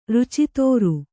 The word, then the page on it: ರುಚಿತೋರು: Kannada